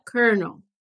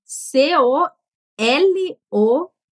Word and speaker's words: colonel: E no inglês a gente fala colonel. Como que escreve essa palavra? C-O L-O-N-E-L. E pronuncia Colonel.